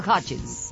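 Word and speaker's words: Hutchence: Michael Hutchins, ahí está.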